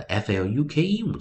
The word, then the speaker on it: fluke: 因此呢，我们拿出首音 f l，首音 f l，我们就念 fl，fl，fl，的 f l u k e，我们就发音为。 fluke, fluke, fluke。再跟老师念一遍，f l fl, fl, fl, fluke, fluke, fluke。所以看我们的 fluke 这个发音的方式，u k e vowel consonant e 母子一元辅一，发出长母音、长元音 long vowel。